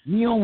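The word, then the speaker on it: Niall: It's N-I-A-L-L. I don't know how to say it. – Neal Leonard.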